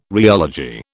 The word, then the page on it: rheology: British English